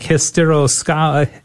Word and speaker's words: hysteroscopy: Some of the words I can pronounce, uh, like hysteroscopy hysteroscopy.